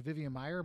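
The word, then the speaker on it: Vivian Maier: Vivian Meyer. Am I saying that right? – Yeah, yeah.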